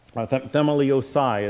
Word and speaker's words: Themeliosai: In fact, I'm going to say it the way you say it in Greek, but a word's going to come to your mind. Themeliosa. Themeliosai is in this particular context.